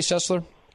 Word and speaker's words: Sessler: Jerrod, did I say your last name the right way, Sessler? – Yes, thanks, Todd.